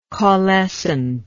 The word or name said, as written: caulescent